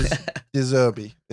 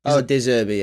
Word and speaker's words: De Zerbi: Roberto De Zerbi, his name. He has very, very good attributes for a coach, for a manager. – De Zerbi, yeah, you're right.